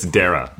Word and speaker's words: Dara: It's Dara, like Sarah with a D. Everyone gets it wrong.